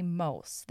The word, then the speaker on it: Muijs: For now, this episode is this year's original first one back with the wonderful Jeremy Mulse, the pronunciation of which you'll understand better after you listen.